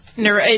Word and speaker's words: neuropathy: How do you say it? Neuropathic? – Neuropathy. – It's neuropathy, but neuropathic pain is how they say it.